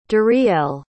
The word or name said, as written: Dorielle